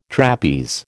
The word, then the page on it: trapeze: American English